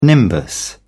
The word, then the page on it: nimbus: British English